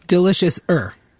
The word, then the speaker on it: Deliciouser: Is a Madison-based chef whose ventures have included Lombardino's, the Tipsy Cow Tavern, now executive chef of the Deliciouser. Delicious. What's the best way to pronounce that? – It's Deliciouser. It's a little more delicious than delicious.